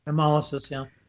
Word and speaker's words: hemolysis: Intravascular hemolysis. How do you say that? Hemolysis? – Hemolysis, yeah. – HIV. Yeah, hemolysis, sorry.